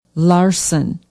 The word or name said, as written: Larsen